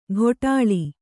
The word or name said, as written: ಘೋಟಾಳಿ